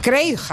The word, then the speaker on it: Craig: Y mm, lo he dicho bien, ¿es Craig o Craig o cómo se. – Craig Hodges. – Craig, así como suena.